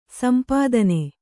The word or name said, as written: ಸೊಂಪುತನ